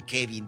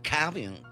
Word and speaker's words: Kevin: No dicen Kevin, dicen Calvin. Que sé cómo le hacen.